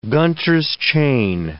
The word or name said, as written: Gunter's chain